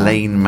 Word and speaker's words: Ghislaine: Ghislaine Maxwell.